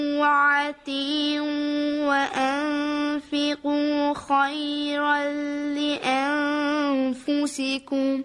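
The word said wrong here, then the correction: أطيعوا: خب این دفعه میدونید شما یک اشتباه کوچکم داشتید و اون همزه ایست که در اول اطیعوا هست، اون رو مثل عین تلفظ کردید. یک بار دیگه بخونید تا تصحیح بشه. – وَأَتِيعُوا وَأَنفِقُوا خَيْرًا لِأَنفُسِكُمْ.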